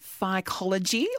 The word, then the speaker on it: phycology: Am I right in saying it's phycology or the study of algae? – You're quite right saying that. Yep.